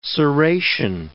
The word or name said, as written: serration